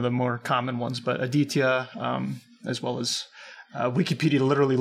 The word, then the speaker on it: Aditya: And I think it's pronounced Aditya, right? Did I put the stress on the right syllable there? – That's how I've been pronouncing it. But okay.